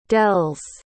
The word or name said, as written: dells